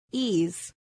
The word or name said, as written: ease